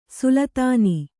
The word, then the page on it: ಸುಲ್ತಾನೀ: sultānī